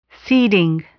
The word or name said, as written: seeding